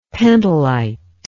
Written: pentolite

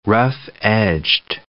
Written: rough-edged

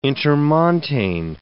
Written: intermontane

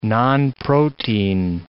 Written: nonprotein

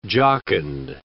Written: jocund

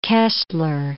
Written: Koestler

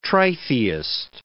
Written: tritheist

